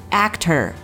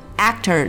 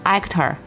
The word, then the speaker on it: actor: 再来看第二个重点单词 actor，我们注意这个 actor 里面的字母 a 发的是一个 a 的梅花音，不要读成 actor 啊。嘴巴张大一点，两指宽的开口度，然后嘴角往两边咧一点，去发一个比较夸张的 a 的音 actor。